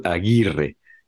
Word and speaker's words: Aguirre: es Aguirre Y aparte eh, eh, pronunciar la R fuerte, ¿no? La R en español, Aguirre.